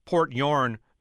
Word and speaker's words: Port Huron: Yeah, my grandpa is from Port Huron, Michigan, which he called till the end of his days Port Yorn, Michigan.